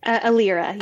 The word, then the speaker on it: Elira: Am I saying that right? – Elyra. Yes. Elyra. So super close.